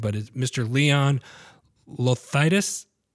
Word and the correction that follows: Logothetis: I butchered it. I know I did. you kind of butchered it, but don't worry about it. It's Logothetis. – Logothetis.